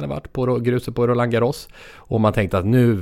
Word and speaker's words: Roland Garros: Jag tror fransmännen själva säger Roland Garros. – Roland Garros.